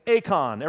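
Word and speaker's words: Akon (εἰκών): Two syllables. Akon, everybody. Akon. And this is, well, it looks a whole lot like icon.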